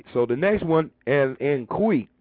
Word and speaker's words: goeie: G-O-E-I-E, but it's pronounced Kwe, like Q. So Kwe Anand is good evening. N-A-A-N-D, Kwe Anand means good evening.